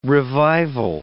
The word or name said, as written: revival